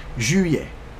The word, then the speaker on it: juillet: In French is juillet.